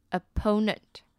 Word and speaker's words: opponent: 那比较特别是 opponent 跟 opposite 前面 OPPO 的发音不一样哦。 opposite 的第一个 O 是发 R，第二个 O 发短音 E，那 opponent 呢反而是在第一个 O 发短音 E，第二个 O 是发 O 的音， opponent， opponent， opposite， opposite。